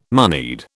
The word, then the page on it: moneyed: British English